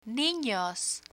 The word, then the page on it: ninos: Spanish